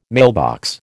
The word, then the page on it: mailbox: American English